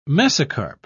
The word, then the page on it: mesocarp: British English